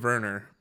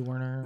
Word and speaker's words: Werner: His name is Timo Werner. – Yeah, that's probably correct. – W-E-R-N-E-R, but they say W-E-R-V. – It's probably the German way is V, but you know the English way is W Werner.